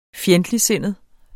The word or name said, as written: FJENDTLIGTSINDET